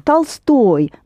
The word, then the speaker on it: Tolstoy: Tolstoy, the same as Bolshoi, the same ending.